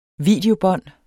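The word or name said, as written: Videobånd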